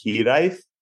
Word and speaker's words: Hiraeth: That's kind of the fun with the name, but it's a Welsh term that's technically pronounced Hiraeth, but I normally say Hiraeth because that's how it phonetically looks.